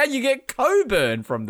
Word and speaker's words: Cockburn: Cockburn. It's pronounced Coburn.